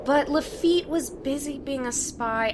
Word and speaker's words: Lafitte: Some say it was first built by the pirate Jean Lafayette. – Really? I don't know. And also, it's pronounced Lafitte, not Lafayette.